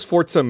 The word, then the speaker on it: Forza: You do know it is actually pronounced Forza, right? – Is that the appropriate pronunciation? – You put a T in it? – It is, because it's Italian. Say pizza. Is there a T in pizza, Ryan? – Pizza.